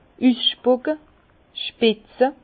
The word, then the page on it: üsspucke, üsspitze: Bas-Rhin; Herrlisheim